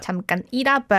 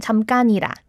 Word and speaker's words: 잠깐이라: Yeah, in the first lyric, I noticed 잠깐이라 was how he pronounced. 잠깐이라, but without splitting it, you can just blend the sound. 잠깐이라.